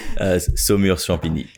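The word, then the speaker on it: Saumur-Champigny: And then the Semur Champigny, please can you pronounce that? Because you do a much better job of it than me. – Semur Champigny.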